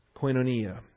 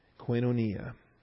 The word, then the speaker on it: koinonia: Koinonia. K-O-I-N-O. It's a long O. N-I-A. Koinonia.